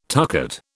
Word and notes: tucket: British English

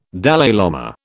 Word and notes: Dalai lama: British English

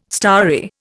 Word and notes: starry: Indian

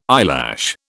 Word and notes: eyelash: British English

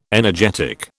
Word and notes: energetic: British English

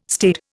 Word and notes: staid: Indian

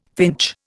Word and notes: finch: Indian

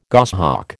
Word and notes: goshawk: American English

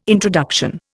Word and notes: introduction: Indian

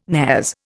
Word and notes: nares: Indian

